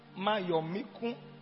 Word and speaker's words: Mayomiku: What that means is that when I am rich, if the way Mayomiku, did I pronounce it well now? Mayomiku. Am I being? Somebody said, yeah.